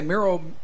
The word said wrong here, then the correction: morels: the mushrooms? – Yeah, it's about like mushroom. – How do you say that? Mural? – The morels? – Morels.